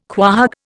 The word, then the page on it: quahog: Indian